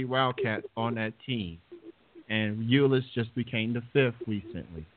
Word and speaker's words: Ulis: Now, how big is this Tyler Ulis? Am I saying that name right? – Yeah, you're saying it right.